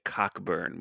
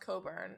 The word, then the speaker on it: Cockburn: Wikipedia, it's Olivia Cockburn, which is way funnier. – Yeah, but it's pronounced Coburn. It's Irish.